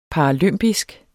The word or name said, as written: paralympisk